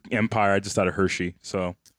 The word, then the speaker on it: Hershey: Morgan, Milton Hersey, also canceled at the last moment and survived to build the Hersey food empire. – Hershey. – Oh, is it a typo? – No, it's Hershey. – Milton Hersey. – That's how you pronounce Hershey.